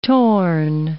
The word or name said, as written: torn